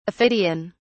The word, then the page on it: aphidian: British English